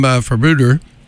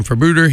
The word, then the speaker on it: Febueder: The band name is spelled F-E-B-U-E-D-E-R. And according to their Facebook page, it's pronounced like Bermuda, but with an F.